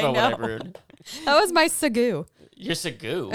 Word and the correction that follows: segue: It's a segue, but I called it a Segu. Oh my gosh. It's, I know me. it's pronounced segue, everyone.